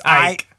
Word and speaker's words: Icke: Is it Icky? Is his name Icky or Ike? It's Ike. Okay, so it's I-C-K-E. It's David Icke.